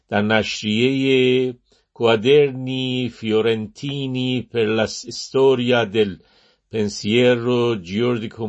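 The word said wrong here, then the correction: giuridico: مدرنو، بله، جوریدیکو مدرنو، امیدوارم که تلفظ ایتالیایی من خیلی خطا نبوده باشه.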